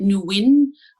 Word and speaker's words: Nguyen: It's G is silent. I'm happy to say it Nguyen, which is, whatever comes out of your mouth without the G, we're fine.